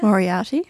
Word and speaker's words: Moriarty: I read one day, I started to read, I'm going to pronounce her name wrong, Leanne Moriarty? Is that how you say it? – Moriarty.